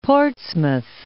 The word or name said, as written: Portsmouth